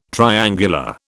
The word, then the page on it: triangular: British English